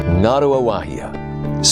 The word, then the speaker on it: Ngaruawahia: I'd like you to join me in my hometown, Ngaruawahia. – Ngaruawahia, spelled N-G-A-R-U-A-W-A-H-I-A.